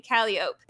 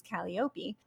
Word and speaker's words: Calliope: And one of them is what most people would probably say Calliope, and we say Calliope. But what's interesting is that that's also closer to the Greek, which is Calliope. And so I decided to use the New Orleans pronunciation, and they're actually muses.